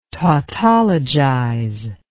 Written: tautologize

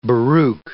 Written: Baruch

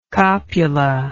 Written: copular

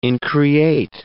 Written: increate

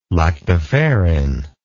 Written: lactoferrin

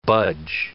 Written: budge

